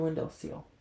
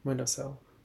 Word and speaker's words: windowsill: And it's just sitting on your window seal. Yeah. How do you say the word? – Windowsill.